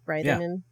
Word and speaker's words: Reitherman: Reitherman.